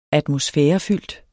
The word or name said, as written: atmosfærefyldt